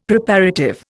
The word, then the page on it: Preparative: Indian